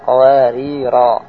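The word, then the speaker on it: qawarira (قَوَارِيرَا): Kalau mau berhenti di pertama, jadi qawarira ayat 15, cara membacanya adalah qawarira. Kalau berhenti pada qawarir kedua, membacanya qawarira qawarira qawarira qawarira min fidda.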